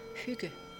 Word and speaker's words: hygge: which is called hygge, which is spelled H-Y-G-G-E.